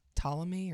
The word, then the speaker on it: Ptolemy: Just like the P is silent in Ptolemy or...